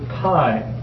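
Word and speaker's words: P (π): That's the letter pi, or P. P is actually how it's pronounced, although sometimes in high school they call it pi.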